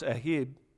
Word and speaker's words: ahab: And this word is spelled Ahab. It's A-H-A-B, but it's pronounced Ahib.